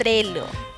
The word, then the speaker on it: Trello: Trello.